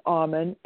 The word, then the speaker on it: Auman: Okay, my name is Catherine, which is spelled with a C, C-A-T-H-E-R-I-N-E. My last name is Auman, A-U-M-A-N.